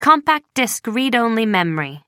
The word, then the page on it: compact disc read-only memory: American English